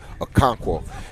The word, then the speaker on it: Okonkwo: We're talking to Tennessee Titans tight end Chig Okonkwo.